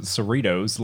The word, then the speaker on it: Cerritos: I'm also pretty sure that they pronounce it Cerritos, like Doritos, but with Cerritos.